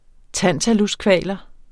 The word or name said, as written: TANTALUSKVALERNE